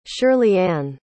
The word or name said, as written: shirleyann